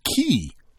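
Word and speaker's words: cay: So, as I always said, a K, C-A-Y, pronounced as key, is defined as a low bank or reef of coral, rock, or sand.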